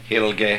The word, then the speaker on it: Heilig: what's called the Heilige. How do you say that? Where are you? How do you say holy in German? – Heilig.